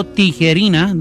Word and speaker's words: Tijerina: Just look me up, Roberto Tijerina. My name is spelled T-I-J-E-R-I-N-A, Roberto.